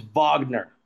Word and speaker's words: Wagner: I was expecting another Lamar pun that was going to be like, you know, third place, bronze, but I want to correct myself real quick. I always pronounce their stinking names wrong. I did it with his brother too. It's Wagner. There we go.